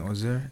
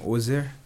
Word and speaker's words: Ozer: So first off, my name is Chimisupten Ozer, and the stage name Oz is actually the short form of my real name Ozer. It's spelled O-Z-E-R, and it's just Oz is just O-double-Z.